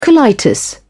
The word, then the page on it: colitis: British English